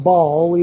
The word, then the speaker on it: Baal: It's actually pronounced Baal, even though it's B-A-A-L.